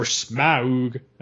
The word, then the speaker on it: Smaug: Smaug, or Smaug, as you're supposed to pronounce it now.